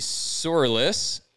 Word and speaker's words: Sourlis: Teddy Sourlis, I hope I said that right.